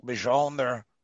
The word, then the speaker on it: Mjolnir: Yes, well, growing up reading Stan Lee and Jack Kirby's version of Thor in Marvel Comics, I know that Mystic Mjolnir, that's the way you pronounce it, wasn't just a weapon.